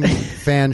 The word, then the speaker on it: Fan: It's pronounced Fong. – Her sibling is Fan Ching Ching.